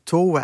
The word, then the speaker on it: Tove: Tove Jansson, ja, je moet het echt Tove uitspreken, ik heb dat geoefend.